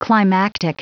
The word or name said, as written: climactic